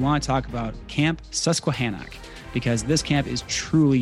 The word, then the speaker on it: Susquehannock: Susquehannock. – Right. – There we go. – There you go. Susquehannock.